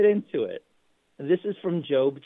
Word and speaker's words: Job: And the first reading is from Job. Now, you know, it's spelled J-O-B, and so it's not job. So it's, you know, it's not pronounced job. It's traditionally pronounced Job.